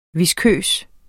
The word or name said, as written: viskøs